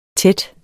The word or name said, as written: tæt